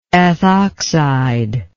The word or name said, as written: ethoxide